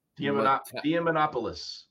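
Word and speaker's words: Diamantopoulos: But Chris Dia Diamonopoulos.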